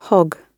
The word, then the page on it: hogg: British English